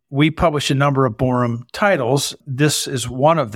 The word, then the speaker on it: Boreham: If you go on Amazon and type in My Christmas Book and his last name, B-O-R-E-H-A-M, Borham, but it's pronounced Borum.